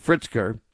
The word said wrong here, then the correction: Pritzker: or Britzker, I guess it is, or Pritzker is how you say it.